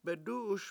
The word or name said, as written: bedU7uS